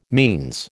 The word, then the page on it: means: American English